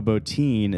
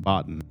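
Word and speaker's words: Bottin: That's how we know Botine is not pronounced Botten, which we've been calling him for the last 2 years.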